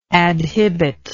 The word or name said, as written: adhibit